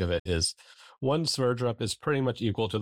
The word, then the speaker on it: Sverdrup: Sverdrup. – Sverdrup.